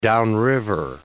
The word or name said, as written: downriver